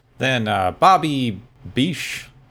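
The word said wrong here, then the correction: Besch: I should have listened to how this name was pronounced. Besh. – Besh.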